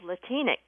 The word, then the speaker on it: Latinx: There's a new adjective that's entered the dictionary called Latinx. It's spelled L-A-T-I-N-X, so it looks like it just has two syllables, L-A-T-I-N-X, but it's pronounced Latinx, and it's an adjective meaning of Latin American heritage or having to do with Latin America.